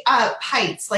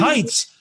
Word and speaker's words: Pites: you got to go check out Chelsea Peets. Am I pronouncing that right, by the way? I don't want to pronounce it wrong, Chelsea. – You know, it's actually Pites. Like it rhymes. – Pites.